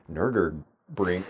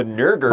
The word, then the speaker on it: Nürburgring: Because it's like specs really, not specs horsepower, but also specs like Nürburgring. Oh man, Nürburgring. I actually said it wrong. It's in my mind wrong. The Nürburgring.